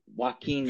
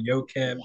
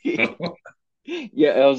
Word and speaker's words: Joaquin: Oh, Joaquin Niemann.